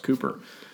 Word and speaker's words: Cowper: I know it's spelled Cowper, but I heard it's pronounced Cooper.